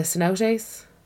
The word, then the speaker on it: cenote: Probably fucking saying it wrong. Do you know that? It's cenote anyway.